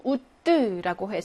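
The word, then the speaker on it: août: 1번에는 우뜨라고 해서 요건 오으가 결합돼서 우 발음이 나는 거죠.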